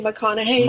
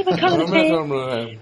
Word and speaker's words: McConaughey: Matthew McConaughey. Matthew McConaughey.